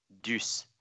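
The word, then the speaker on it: Duce: French, it would only be pronounced that way if there was an accent on the E. D-U-C-E is just Deuce. That would be how you pronounce that in French.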